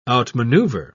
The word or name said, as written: outmaneuver